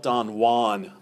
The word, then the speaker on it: Don Juan: Spelt Don Juan, but pronounced Don Juan, because he rhymes it with incessantly with phrases like new one and true one. So those are all rhyming with Jew one.